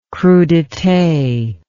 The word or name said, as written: crudites